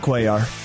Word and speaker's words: Cuellar: Henry Cuellar, is that how you pronounce his name? – Cuellar? Yeah, Cuellar.